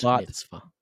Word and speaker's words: bat mitzvah: And next week, we're going to be taking a look at Gran Turismo, and you are so not invited to my bat bar mitzvah on Netflix by Adam Sandler. – Bat mitzvah.